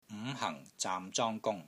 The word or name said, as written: NG5 HANG4 ZAAM6 ZONG1 GUNG1